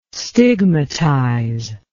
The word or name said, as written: stigmatize